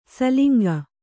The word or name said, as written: ಸೂಲಂಗಿ